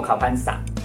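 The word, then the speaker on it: 考 潘 萨 (เข้าพรรษา): 泰国有一个很重要的佛教节日守夏节，读作考潘萨，是进入雨季的意思。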